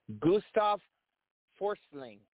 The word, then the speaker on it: Gustav Forsling: Also, I want to congratulate, let's see if I pronounce this right, Gustav Forsling.